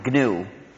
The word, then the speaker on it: GNU: In any case, when it's the name of our operating system, please pronounce a hard G. Pronounce it GNU. If you speak of the new operating system, you'll get people very confused.